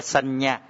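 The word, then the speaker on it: saññā: Lấy cái từ là tưởng, tiếng Việt cho mọi người dễ hiểu, tiếng Pali dễ như sanh nhà, đọc là sanh nhà. À, có hai cái dấu ngã đọc thành chữ nhờ.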